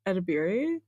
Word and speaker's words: Edebiri: Edebiri.